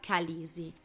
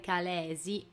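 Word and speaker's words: Khaleesi: Fa eccezione però la parola calisi. Lo spelling di questa parola è K-H-A-L-E-E-S-I. Le due E centrali, come sappiamo, si pronunciano in inglese I quindi teoricamente si dovrebbe pronunciare Calesi, ma un anglofono la pronuncerebbe Calisi e tale è rimasta nella pronuncia anche nella serie.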